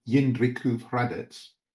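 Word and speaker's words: Jindřichův Hradec: Yindrikuv Hradets.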